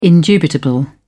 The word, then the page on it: indubitable: British English